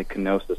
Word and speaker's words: kenosis: So I had a quick question, and it's regarding the kenosis theory. I hope I'm pronouncing that correctly. – Yep, that's correct.